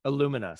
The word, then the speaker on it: Illumina: Plus the broadcast experience into what you're doing now with Illumina. Am I pronouncing it right? – Illumina.